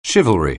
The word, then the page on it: chivalry: anglais